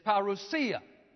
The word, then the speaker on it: parousia: is parousia, okay? Parousia, phonetically it's spelled P-A-R-O-U-S-I-A. Parousia, okay?